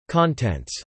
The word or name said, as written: contents